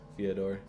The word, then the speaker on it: Fyodor: Fyodor. – It's like the Russian Theodore.